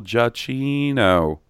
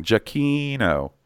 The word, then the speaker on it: Giacchino: So that gives me lots of hope for my good buddy, Michael Giacchino. Oh, my God. I just said it wrong. Giacchino.